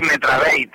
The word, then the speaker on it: Metrabate: No tiene ninguna pronunciación en inglés ni nada por el estilo, sino tal cual se escribe, se lee. – Sí, sí. Hombre, suelen decir por ahí Metrabate y tal, pero no, da igual, tanto uno como otro, está bien.